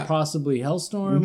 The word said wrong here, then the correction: Hellstrom: Hellstrom. Which I just noticed today. I've been saying it wrong my entire life.